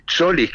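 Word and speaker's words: Tzolis: tiene un griego Solís, que se escribe TZ O-L-I-S, Solís, que está muy bien, pero Julián obviamente tiene otra capacidad y podría jugar perfectamente sobre la izquierda.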